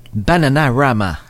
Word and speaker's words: Bananarama: Bananarama. – Oui, c'est ça. – Il te manquait une syllabe.